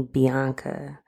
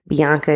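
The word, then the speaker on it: Bionca: All of my handles are Call Me Bianca. Bianca is spelled with an O, B-I-O-N-C-A.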